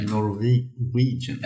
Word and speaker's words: Norwegian: Hur uttalar man det? Norwegian? Norwegian. Ja, med svenska finuttalet.